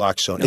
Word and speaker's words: naloxone: What? How do you say it? – Naloxone. It's available at most places now.